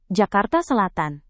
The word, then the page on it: jakarta selatan: Indonesian